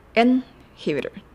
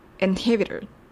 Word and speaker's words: inhibitor: んのところで舌先を前歯の裏につけて発音します。エンヒビル。エンヒビル。